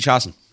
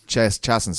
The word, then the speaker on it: Chiasson: How does he say your second name? Chiasson? – Macy Chiasson. – Chiasson, sorry. Yeah.